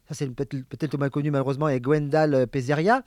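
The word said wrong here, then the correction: Peizerat: Pézera. – Qu'est-ce que je dis ? Pézeria. – Non, c'est Pézera. – Non, c'est Pézera, oui, il me semble.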